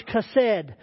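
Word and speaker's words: chesed (חֶסֶד): It's in Hebrew, it's chesed. I know it's H-E-S-E-D on the screen there, but it has a little deal under the H, which means it sounds like a K, C-H-A. Chesed.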